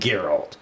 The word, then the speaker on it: Geralt: "As the greatest of literary characters, I pass the torch with reverence for the time spent embodying Geralt."